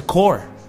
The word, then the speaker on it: Corps: Y la palabra core, aunque se escribe a corpse, se pronuncia core.